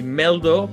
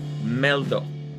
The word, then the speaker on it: Mehldau: que se, se escribe Brad como Brad Pitt y Meldo como M-E-H-L-D-A-U, Meldo.